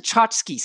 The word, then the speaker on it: tchotchke: And then we talked about a word that was spelled T-C-H-O-T-C-H-K-E. – From my book. – From your book. And it's pronounced tchotchke.